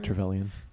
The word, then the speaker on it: Trevelyan: So this is kind of like at the level where you get to have the showdown with Alec Trevelyan, if I'm pronouncing his name. – Yeah, right? – Trevelyan.